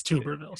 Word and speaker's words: Tuberville: It's Tuberville, but... – Tuberville. No, it's Tuberville, sir.